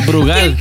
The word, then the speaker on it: Brugal: Y llevamos el, el Bruggle. ¿Cómo se dice? ¿A quién? Al Bruggle. Al Bruggle. Al Brugal.